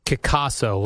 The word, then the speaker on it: Kickasso: Kickasso, like it's pronounced like Picasso.